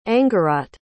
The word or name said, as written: angharat